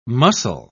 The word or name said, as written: muscle